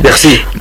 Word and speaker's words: Piercy: Mujer al borde del tiempo, de Marge Piercy, o Piercy, no sé muy bien cómo se pronuncia. – Piercy.